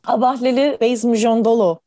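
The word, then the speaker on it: Abahlali baseMjondolo: İsminin çok zor okunduğu bir aslında hareket bu. Abahleli Reis Mujondolo diye okunuyor.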